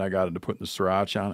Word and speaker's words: Sriracha: And you bread and fry that fillet, and you put it on your hoagie roll with pickles, onion, lettuce, sriracha. Am I saying that right? – Yeah.